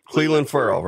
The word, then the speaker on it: Clelin Ferrell: Because I thought I heard an interview where you said it was Clelin Ferrell. A lot of people are still going with Clelin Ferrell, though. Which way is it? – You said it right the first time.